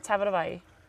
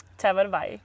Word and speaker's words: Tabatabai: Sara Tabatabai. Tabatabai. – Tabatabai. – Tabatabai. – Nice! – Tabatabai, yes. – Okay. – Rather than like an I sound at the end, it ends with an E sound.